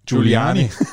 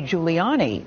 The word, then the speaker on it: Giuliani: Giuliani.